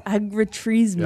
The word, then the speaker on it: Agriturismo: Agriturismo.